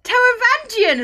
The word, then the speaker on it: Taravangian: Tavgarian? I can't read his name, so I just shorten it to Tavman. – Taravangian? – Taravangian! Is that how you say it? – Yes, Taravangian from Stormlight.